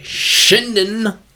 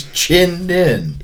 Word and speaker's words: Chinden: It's that's the people that I've talked to are like the native that are like Shinden. – We're natives. – Well, you guys are pronouncing it wrong. – It's Chinden.